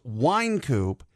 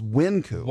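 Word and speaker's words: Wynkoop: he says the street is pronounced Wine Coop, the pub restaurant thing is Wind Coop.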